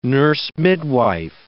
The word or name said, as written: nurse-midwife